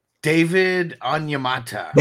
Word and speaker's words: David Onyemata: It's David Onyemata. – He it is. Onyemata.